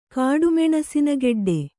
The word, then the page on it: ಕಾಡುಮೆಣಸಿನಗೆಡ್ಡೆ: kāḍu meṇasina geḍḍe